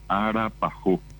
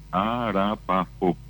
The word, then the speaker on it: arapajó: Y eso se convirtió acá popularmente en arapajó. Y viene, es la pronunciación de alfajor, pero en la fonética del guaraní, arapajó.